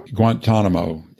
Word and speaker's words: Guantanamo: Guantanamo.